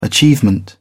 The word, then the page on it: achievement: British English